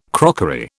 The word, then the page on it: crockery: British English